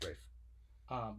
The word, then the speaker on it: Ralph: It says Ralph, but it's actually pronounced Ralph.